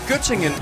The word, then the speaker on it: Göttingen: Var det ved Gødringen? – Nej, Göttingen. – Göttingen, ja, men det... – Göttingen ost. – Jamen, det er bare mig, der ikke kunne udtale det korrekt.